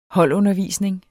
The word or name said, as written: holdundervisning